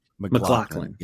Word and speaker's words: MacLachlan: But yeah, Kyle MacLachlan, how do you say MacLachlan? – MacLachlan, yes, was wonderful.